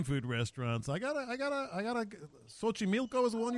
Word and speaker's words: Xochimilco: I'm impressed that you pronounced it right. Because my partner would have said Xochimilco.